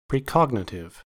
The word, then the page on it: precognitive: American English